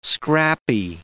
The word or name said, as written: scrappy